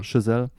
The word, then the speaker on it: Chazelle: Chazelle.